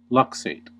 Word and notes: luxate: American English

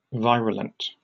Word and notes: virulent: British English

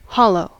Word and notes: hollow: American English

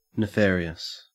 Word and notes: nefarious: Australian English

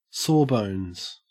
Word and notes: sawbones: Australian English

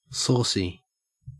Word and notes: saucy: Australian English

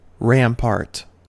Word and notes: rampart: American English

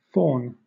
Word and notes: faun: British English

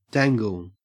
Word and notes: dangle: Australian English

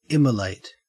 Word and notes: immolate: Australian English

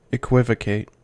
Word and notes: equivocate: American English

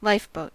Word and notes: lifeboat: American English

